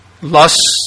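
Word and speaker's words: Löss: This is actually a Lurs, that's pronounced Lus by Americans and Lurs by Germans, is a German term.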